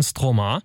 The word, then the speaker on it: Stromae: Lasst euch aber noch ein bisschen Angeberwissen da zu dem Interpreten, den wir euch jetzt spielen, Stromae.